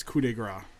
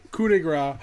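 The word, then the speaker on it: coup de grâce: so my number one currently, Finn Balor's coup de grace. One of my favorites. Did I say that right? I said that. – Yeah, right. – you said that right. Coup de grace.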